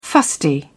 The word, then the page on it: fusty: British English